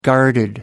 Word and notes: guarded: American English

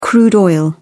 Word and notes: crude oil: British English

